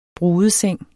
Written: BRUDESENG

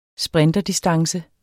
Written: sprinterdistance

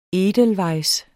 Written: Edelweissen

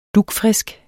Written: dugfrisk

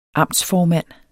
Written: amtsformand

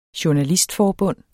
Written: journalistforbund

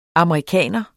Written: amerikaner

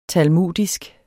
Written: talmudisk

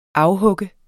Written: afhugge

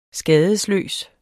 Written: skadesløs

